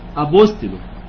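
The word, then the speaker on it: أبوستلو (ἀποστέλλω): أبوستل، حرف الألفا وحرف البي، حرف الأوميكرون، سيجما، تاو، إبسلون، لامدا، لامدا، أوميجا، ولامدا لامدا يا جماعة بتتنطق لامدا واحدة بس، ماشي؟ لامدا لامدا بتنطق ايه؟ لامدا واحدة بس، تمام؟ أبوستلو، ماشي ايه يا جماعة؟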